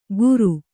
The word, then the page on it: ಗುರು: guru